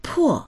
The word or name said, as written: po4